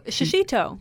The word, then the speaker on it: shishito: How are they pronounced? – Oh, shishito. Yeah, shishito yeah, exactly.